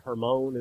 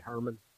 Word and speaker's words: Hermon: I believe it was Mount, you'd say Hermon is the actual proper pronunciation here in Granger County. We just say Hermon, right? Mount Hermon. It was a, like Hermon Munster, that's how we say it.